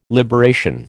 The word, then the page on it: liberation: American English